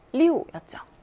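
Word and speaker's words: 리우 (六): 숫자 6의 발음은 리우였죠. 리우, 사성입니다. 예, 순조롭다. 예, 우리 물 흐르다라는 뜻의 단어. 리우, 이물 흐를 류자가 있거든요. 이 발음이 리우예요. 이 발음하고 리우하고 발음이 비슷하죠. 예, 성조만 달라요.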